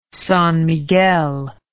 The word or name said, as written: San Miguel